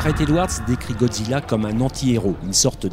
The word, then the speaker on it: Godzilla: Enfin, pour être complet, sachez que Godzilla se prononce en japonais Godzilla.